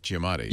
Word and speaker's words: Giamatti: Giamatti. – Something like that. – Giamatti.